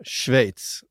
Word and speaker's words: Schweiz: Schweiz. Det är nämligen så det ska uttalas.